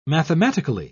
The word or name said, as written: mathematically